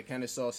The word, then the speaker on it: Kennesaw: Yeah, so week two, September 12th, they play Kennesaw State. Is that how you pronounce it? – Yeah, Kennesaw State.